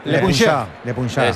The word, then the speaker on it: Le Puncheur: Le leemos ahí en, no sé cómo se dice, Le Puncheur, no sé cómo se dice eso. Sergio. – No, lo has dicho bien, lo has dicho bien. – No, se puede mejorar, Le Puncheur. – Le Puncheur.